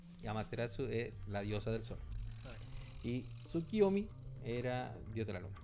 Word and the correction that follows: Tsukiyomi: Tsukiyomi, recuerda que la U no se pronuncia.